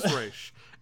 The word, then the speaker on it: Ra's: It really depends on who's saying it, how they pronounce it. – It's Ra's. – It is Ra's.